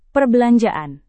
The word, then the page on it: perbelanjaan: Indonesian